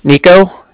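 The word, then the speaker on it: Niko: Nico. So that's N-I-K-O. – Mm-hmm. – And then the Bank of Cardiff is spelled C-A-R-D-I-F-F, as in Frank.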